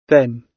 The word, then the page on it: then: British English